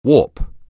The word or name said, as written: warp